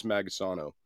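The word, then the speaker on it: Magisono: This man was Gus Magasano. All right. Gus Magasano. I could be saying that wrong, but I'm pretty sure it's Magasano. He was a paid ATF informant.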